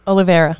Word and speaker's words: Oliveira: How do you say his name? – Oliveira.